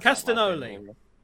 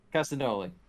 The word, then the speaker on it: Castagnoli: I can't pronounce Castagnoli. – Castagnoli. Thank you. It's like Castellanos, but with a noli.